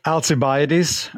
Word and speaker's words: Alcibiades: How do you say his name? Is it Alcibiades? Alcibiades? I'm embarrassed to say I don't know exactly. – I'd say Alcibiades, although that's an anglicized translation, you know, version of the name.